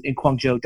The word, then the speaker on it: Guangzhou: But if you're in town, if anyone's passing through Guangzhou, is that how we pronounce it? – Yeah. – If anyone's passing through there, where should we? – Guangzhou.